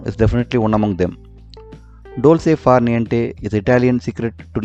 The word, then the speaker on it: dolce far niente: The word of the day is from the Italian language and is pronounced dolce far niente.